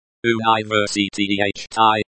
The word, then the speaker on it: университете: Университете.